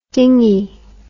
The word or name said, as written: dingey